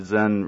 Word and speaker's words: Zen: Zen rhymes with den, so think about that.